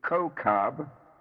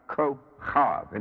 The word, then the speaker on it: Kokab: The mundane sphere is Mercury, the Hebrew being Kokab, Kokab, Kokab, or sometimes Kochav. In other words, the K is a kaf, kaf, which has two pronunciations.